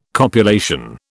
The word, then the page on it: copulation: British English